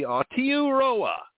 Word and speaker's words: Aotearoa: And a fantastic hello to Stuart Mitchell in Martin Rangtike in New Zealand, uh, pronounced uh, the Maori way, if I'm saying it the correct way. It should be Aotearoa is New Zealand.